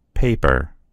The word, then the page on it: paper: American English